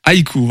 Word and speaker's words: Haïku: sublimée c'est comment Haïku. Haïku, voilà. Sublimé, euh, Lumière de Dick également.